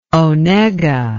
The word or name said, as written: Onega